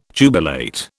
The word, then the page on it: jubilate: British English